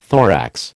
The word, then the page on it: thorax: American English